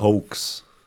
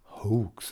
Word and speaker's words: Hoax: Hoax. Hoax, sagt man das. Sagt man das so, genau. Hoax. Das ist ein Hoax.